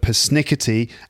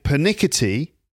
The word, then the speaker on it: persnickety: Now, Sarah said the word persnickety, actually pronounced with her American accent, persnickety. And I stopped her because I said, what, persnickety? What does that mean? And then I started saying pernickety.